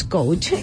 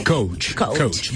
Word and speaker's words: coach: Bien pronunciado como anglicismo es coach. – Coach. Vale, pues. – Y así en español, coach.